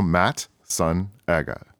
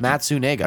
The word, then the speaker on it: Matsunaga: I don't know if you experience this as well, but so to me, I feel as though phonetically my last name is pretty easy. You know, Matt, son, Aga.